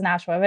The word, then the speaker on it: Nashwa: Everybody says Nashwa, but it's Nashwa, but it's fine.